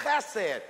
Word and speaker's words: chesed: It's chesed. You got to get a little spit in your throat. Judy, that's how you, chesed. Try it again, chesed.